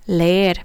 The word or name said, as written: leer